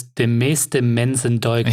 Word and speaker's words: De Meeste Mensen Deugen: The original Dutch title is De Meeste Mensen Deugen. – Yeah, yeah. – Did I pronounce that right? – Exactly, yeah.